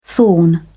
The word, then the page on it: thorn: British English